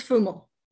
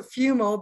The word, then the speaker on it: Fumo: It's, it's Fumo. Okay, I didn't want to butcher it. I'm the queen of butchering names. – No, that's okay. – Most so, uh, most English people pronounce it Fumo, but in Italian it's Fumo.